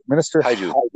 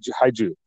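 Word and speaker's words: Hajdu: Minister, I always pronounce her name wrong. Minister Haiju. – Haiju. Yeah. She, sounds like a monster from Pacific Rim.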